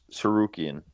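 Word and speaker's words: Tsarukyan: I don't know how to say that. – Sarukyan. – Sarukyan. There you go. That's so easy to say once I'm told how to say it.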